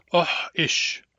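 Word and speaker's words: oish: Oh-ish. In some dialects, this is pronounced short-long, but in Barkly Sound dialects, it's pronounced short-short.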